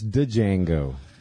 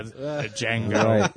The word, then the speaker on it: Django: And he goes, "Django." The D is silent. And I thought he said, "The D is side." – Oh, so it's Django. – Yeah, Django.